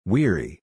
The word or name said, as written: weary